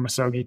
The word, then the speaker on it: misogi: I don't know if I pronounce it right, um, but it's misogi. – Yeah, misogi.